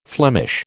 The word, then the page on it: Flemish: American English